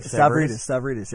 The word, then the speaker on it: Stavridis: As the Admiral Stavridis, did I say that right? – Stavridis, yes, sir.